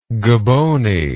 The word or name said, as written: gobony